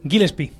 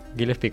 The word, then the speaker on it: Gillespie: Gillespie. – Gillespie, como he dicho yo.